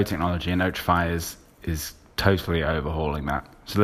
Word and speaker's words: Otrafy: We've got the co-founder of a company called Otrafy.